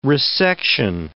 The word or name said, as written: resection